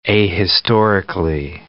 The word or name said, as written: ahistorically